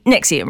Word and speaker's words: NXIVM: And I always feel like I'm not sure if I'm saying that right, because it's spelled N-X-I-V-M, but Nexium, right? – That is correct, yes.